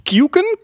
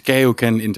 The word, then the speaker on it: KeokeN: Ja, ik, is het nou Kyuken? Kyuken? – Ja, het is, het is Keoken Keoken.